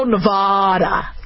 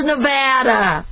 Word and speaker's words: Nevada: A lot of you guys go Nevada, it's Nevada, come on, Nevada, you know, it's not Arkansas, it's Arkansas, but we know about that.